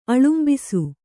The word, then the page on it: ಆಲಂಬಿಸು: ālambisu